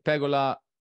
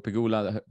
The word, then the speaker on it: Pegula: the women's matches Swiatek pegola or pegula that's how you pronounce